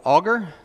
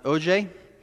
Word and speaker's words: Auger: Now, this past summer, we held our first Wasaya Bible Church, or Bible Camp, at a little camp called Augur. Or I'm sorry, it looks like Augur, A-U-G-E-R, but it's pronounced O-J.